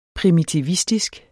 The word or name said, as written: primitivistisk